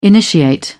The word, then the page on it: initiate: British English